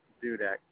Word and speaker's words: Dudek: Robert Dudek, D-U-D-E-K.